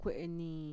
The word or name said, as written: kue'enii